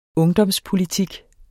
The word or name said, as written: ungdomspolitik